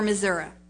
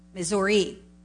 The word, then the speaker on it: Missouri: I spoke at a little church in Raymore, Missouri. See, I say it like I used to say it. Missouri. When you live there, it's Missouri. But the first time,